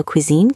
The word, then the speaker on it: cuisine: French cooking was very popular back then, so cuisine kept its French pronunciation, cuisine. We tend to keep the French sound when a word still feels very French.